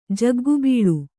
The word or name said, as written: ಜಗ್ಗುಬೀಳು